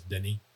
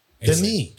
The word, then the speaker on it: Denis: It's his first name that I always get wrong. It's not because it's spelled like Dennis, but it's Denny. – Denny.